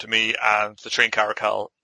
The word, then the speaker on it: Caracal: Is it Caracal? – Caracal. – Caracal. – Yeah.